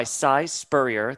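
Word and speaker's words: Si Spurrier: It's written by Cy Spurrier. Thank you, Flink, for telling me how to pronounce that.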